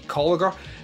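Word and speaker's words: Kolker: Bruce Dern is amazing in it, but what almost stole the show for me is the little girl, Lexi Colliger, or Colker, I think that's how you pronounce it.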